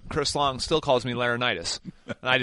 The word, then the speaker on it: Laurinaitis: It was L-O-R-E in capital letters, lore, Nitis.